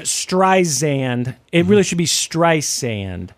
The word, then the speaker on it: Streisand: I guess that it was saying Streisand and it should be Streisand.